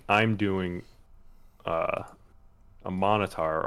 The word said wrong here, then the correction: Minotaur: or however you pronounce it. Minotaur? I think you're right.